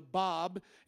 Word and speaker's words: Bab: Bab. Anybody hear of the Bab? It's not B-O-B, it's actually B-A-B, and it's pronounced Bab, the Bab, and he thought he was a Messiah-like figure.